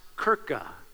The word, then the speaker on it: Kirche: And it was picked up and adapted by an Eastern Germanic tribe around 300 AD, and the word would have been pronounced Kirche. It was K-I-R-C-H-E.